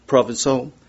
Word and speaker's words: Provençal: First, he commissioned the translation of several books of the Bible into the local dialect, which was French provincial, Provençal, P-R-O-V-E-C-A-L, how do you say it? Provençal.